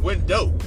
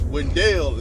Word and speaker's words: Wendell: and you just can't holler out Wendell on a train because it sounds like window. So the conductors and such, they used to holler out Wendell so people could understand that it's Wendell instead of window.